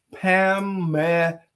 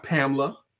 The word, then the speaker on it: Pamela: Her name is Pamela. Three syllables. – Syllables. – Three syllables. Pamela. It's not Pamela.